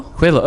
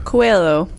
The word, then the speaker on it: Coelho: And how do you pronounce his name? Paulo Coelho? Kello? – It's Brazilian. – Yeah. – Coelho? – Coelho. Oh, look at you. – Coelho.